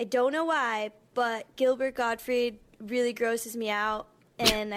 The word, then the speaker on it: Gottfried: But I like that she said Gottfried, pronounced it correctly.